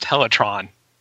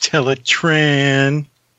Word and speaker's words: Teletran: When Primal finally gains control of himself and tells Black Arachnia that Megatron gained control of Teletran 1, he actually says Teletran instead of Teletron. Every other time it's said in this episode, it's pronounced Teletron. – Yep. I noticed that too. – It's pronounced Teletran.